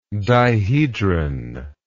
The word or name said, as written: dihedron